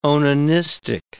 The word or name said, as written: onanistic